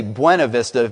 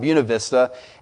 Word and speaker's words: Buena Vista: You would read it and it would say Buena Vista, but that's not how they pronounce it. It's pronounced Buena Vista.